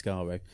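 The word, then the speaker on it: Skaro: Terry Nation, just kick on your box 3 times if you'd intended it to be pronounced Scaro. – Oh, just 2, so it's Scaro.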